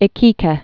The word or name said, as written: Iquique